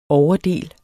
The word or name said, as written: overdel